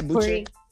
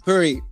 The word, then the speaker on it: Puri: Puri, did I say it right? – Yeah, Puri. Puri.